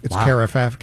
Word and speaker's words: carafe: C-A-R-A-F-E. It's carafe, carafe.